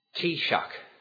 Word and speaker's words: Taoiseach: We had the Prime Minister, or pronounced as Taoiseach, and President of Ireland, Edward Valera.